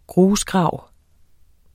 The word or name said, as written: GRUSGRAVEN